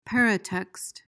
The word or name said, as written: peritext